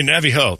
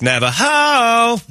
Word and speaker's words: Navajo: Hey, Navi Ho. No, it's pronounced Navajo.